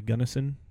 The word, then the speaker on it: Gunnison: which I believe is Black Canyon of the Gunnison National Park, I think is how you say it.